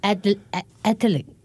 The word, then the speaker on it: athlete: Actually, it's only two syllables. Athlete. – Athlete. – Athlete. – Athlete. – Athlete. – Athlete. – Two syllables only. Athlete. – 对 two syllables. But the common mistake is athlete. I'm an athlete. – Which is three syllable, which is wrong.